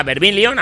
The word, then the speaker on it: Vermilion: Lo que sonaba antes era el tema Vermilion, ahora sí lo digo bien, menos mal, que me perdone.